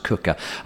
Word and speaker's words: Quooker: It's spelled Q-U-O-O-K-E-R. It's Dutch, you know, and that's why it's spelled that way, but pronounced Cooker. I bet Cooker isn't really the way you pronounce it. That's as close as you get. It's probably Hooker.